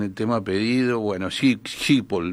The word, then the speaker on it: Schiphol: Se escribe S-C-H-I-P-H-O-L, Schiphol, el aeropuerto de Schiphol, vamos a decirle Schiphol.